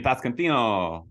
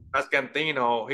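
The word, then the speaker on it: Pasquantino: Vinny Pascantino. – Pascantino.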